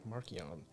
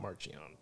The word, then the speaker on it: Marchion: And we learned things of what I guess you could say is the official pronunciation of words like Markion and not Marchion.